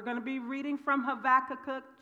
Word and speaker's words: Habakkuk: I pronounce his name Habakkuk. Okay, that's close, right? The Jewish or the Hebrew pronunciation is Habakkuk. Habakkuk. The B is a V.